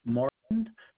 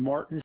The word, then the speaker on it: Martin: My name is Nicholas Martin. Nicholas is spelled N-I-C-K-O-L-A-S, and Martin's M-A-R-T-I-N.